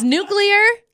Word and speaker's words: nuclear: It is nuclear. Look at the way it's spelled.